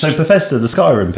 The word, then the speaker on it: Bethesda: Known as Bethesda? I don't actually know how you pronounce their name. – Bethesda. – Yes. I'm going to go with Bethesda.